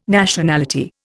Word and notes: Nationality: Indian

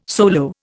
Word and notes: solo: Indian

